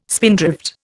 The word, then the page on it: spindrift: Indian